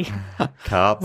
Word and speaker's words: Karpf: Karpf. – Ooh, Karpf. – Right. How do you spell that? – Lorna Karpf. K-A-R-P-F.